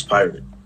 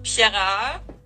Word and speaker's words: Pirat: How'd you come up with that name, Miss Pirate? – It's because my last name is Pirat, which is in German. It sounds like Pirat, which means pirate.